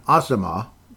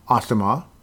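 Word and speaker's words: Asamoah: Brian Asama, apparently, is the way he's pronounced, the way he pronounces his name. So, Brian Asama, and if I'm saying it wrong again, Brian, please correct me, though I'm sure he won't.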